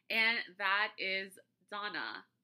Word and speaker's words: Dana: So it's spelled D-A-N-A. So for the longest time, I said Dana. Just, you know, Americanized everything. That's what Americans are wont to do. But it's pronounced Donna or Donna, and that's my mom's name.